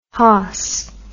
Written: hoss

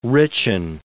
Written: richen

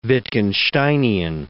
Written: Wittgensteinian

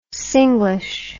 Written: singlish

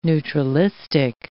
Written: neutralistic

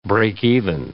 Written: break-even

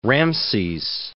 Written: Ramses

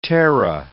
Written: terra

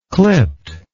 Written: clipped

